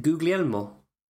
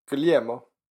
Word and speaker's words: Guglielmo: I actually work with an Italian who has the same forename, and he does pronounce all the letters. – Okay. So how does he pronounce it? – Yeah, so he pronounces it Guglielmo. – Guglielmo. – But he says Googly, okay. Which I was very surprised about. I was ready to call him Guglielmo, but no, he pronounces letters.